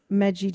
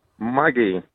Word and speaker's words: Maggi: So different countries pronounce Maggi differently. That's a hard G there for Ghana.